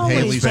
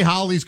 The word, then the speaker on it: Halley: You're going to say Halley's Comet? – No. – Yeah, he is. He's pretentious. He is of course going he is. – To. I'm going to say it's a comet that rhymes with rallies.